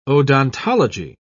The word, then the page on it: odontology: British English